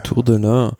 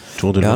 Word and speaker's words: Tour de l'Ain: Die Tour de, wie spricht man es aus? – Tour de l'Ain. Tour de l'Ain.